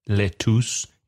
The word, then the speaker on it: lettuce: Another difficult word to pronounce, lettuce, because it's L-E-T-T-U-C-E. So the pronunciation is not lettuce, it's lettuce. Lettuce. – Nabo. – Turnip. – Can you spell that? – T-U-R-N-I-P, turnip.